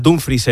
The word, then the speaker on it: Dumfries: Está ya Dumfries en Madrid, que él mismo ha dicho que se dice así, se dice Dumfries, no Dumfries, y el club trabaja en cerrar la llegada de Diomandé y en iniciar las conversaciones con el Manchester City por Rodri.